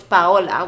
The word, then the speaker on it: Paola: Not trying to bash anyone out there, but like, you speak Spanish, so I'm confused as to why you're saying my name as Paola when you know it's Paola. We say it in Spanish.